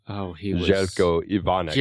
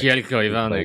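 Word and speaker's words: Željko Ivanek: I loved the short and tragic appearance of Zeljko Ivanovic, or Ivanek. I can't really ever say his name. Zeljko Ivanek. – Zeljko Ivanek.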